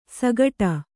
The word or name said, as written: ಸಗಟ